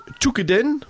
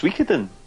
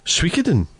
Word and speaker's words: Suikoden: Yeah, also it is Tukiden, would that be right? Tukiden 2? – I think it might be pronounced Suikoden. – Suikoden? – Yeah, I think so. Oh, I may be wrong.